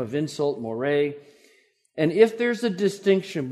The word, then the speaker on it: more: It's more, M-O-R-E, with an accent on the E, more.